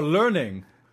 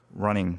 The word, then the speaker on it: running: Wait, running or learning? running.